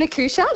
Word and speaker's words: accoucheur: You're an accouture. – Yes, an accoucheur, that's correct. – That's how you say it.